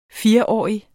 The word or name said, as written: fireårig